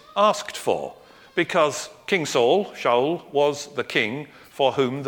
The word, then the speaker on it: Shaul: They call him Saul, but they don't pronounce it that way. They pronounce him as Shaul again.